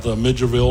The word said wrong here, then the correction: Milledgeville: or whatever? – Milledgeville. – So Milledgeville.